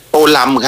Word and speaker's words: โตลำ: อ่านว่าโตเลิมหรือโตลำคะโตลำครับผมอยู่ที่เวียดนามเรียกท่านโตลำครับ